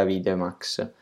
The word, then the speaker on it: Avidemux: Una volta che iMovie mi fa eh, il, il file esportato, passa, se un video fatto dal computer, passa su Avidemax o si scrive Avidemux, ma credo che si dica Avidemax.